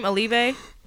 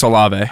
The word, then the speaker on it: Olave: Olave. – Chris Olave.